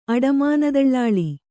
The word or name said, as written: ಅಡಮಾನದಳ್ಳಾಳಿ